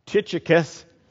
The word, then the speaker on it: Tychicus: Tychicus, say that five times. I had to pull that up on the computer to find out how to pronounce that correctly.